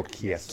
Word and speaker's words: Chiesa: So we got Condit and he's fighting um, Ch- Chess. – Michael Chiesa. Chiesa. Chiesa. I always forget to, how to say his last name. Chiesa. Chiesa. It looks like Chelsea yeah.